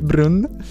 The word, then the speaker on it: Brun: Poi c'è Brun, come si pronuncia? – Brun. Con la U, con la diesi.